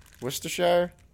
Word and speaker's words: Worcestershire: How is it supposed to be pronounced? – Worcestershire. – Worcestershire? Freaking nailed it.